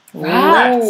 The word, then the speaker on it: wrapped: Wrapped. That is spelled W-R-A-P-P-E-D.